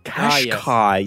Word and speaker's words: Qashqai: How do I say it? I believe it's Qashqai. – Qashqai. Yes. I saw the badge of one of those the other week and I was thinking about, it is an interesting way to pronounce it with the double Q. – Yeah, I think it's that. I'm going to name the car a cash cow because it sounds similar.